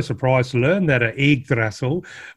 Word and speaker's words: Yggdrasil: Return of the Yggdrasil.